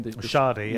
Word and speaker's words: Schade: You know, you saw Wissa and Mbeumo looking really sharp, and, and they brought in is it um, Shard? Is that, is that how you say his name? Schade, yeah, yeah.